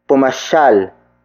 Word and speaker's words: pumasyal: Word for word, it's pumasyal ako sa inyo kahapon. Pumasyal ako sa inyo kahapon. Pumasyal ako sa inyo kahapon. The first word is pumasyal. Pumasyal. Pumasyal. Pumasyal is spelled as P-U-M-A-S-Y-A-L.